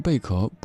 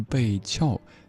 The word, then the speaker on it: 贝壳: 是贝壳，不是贝壳，地壳当中才念壳。